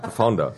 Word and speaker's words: The Founder: aber er ist Betonung nicht, liegt auf The. – Also ist The Founder. – Ja, The Founder.